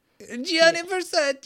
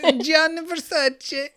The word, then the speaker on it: Gianni Versace: Gianni Versace. – That is correct. – Oh. – Say it again in English. – Gianni Versace.